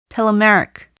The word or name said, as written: telomeric